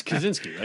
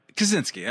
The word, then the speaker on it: Kaczynski: It's Kaczynski, right? – Kaczynski. – It's just like the Unabomber. – Yeah, but see, again. Like, well, you differentiate by saying Kaczynski to try to make it more Polish.